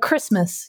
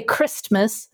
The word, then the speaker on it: Christmas: And it's the same thing with like Christmas. You don't say Christmas, it's Christmas, right? Because you append that. And so it is correct to not pronounce the t.